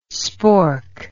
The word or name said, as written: spork